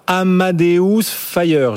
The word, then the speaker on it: Amadeus Fire: Vous nous faites découvrir aujourd'hui un titre sur lequel vous êtes d'ailleurs à l'achat, Amadeus Fire. Je ne sais pas si je le prononce bien, Paul. – Oui, c'est ça.